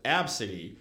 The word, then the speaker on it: ABCDE: And mom did the super original name of doing like her name literally A, B, C, D, E, yeah. – but pronounce it Absidy.